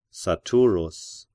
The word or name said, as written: Saturus